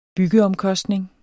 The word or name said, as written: byggeomkostning